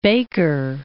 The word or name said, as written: Baker Island